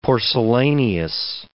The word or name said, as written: porcellaneous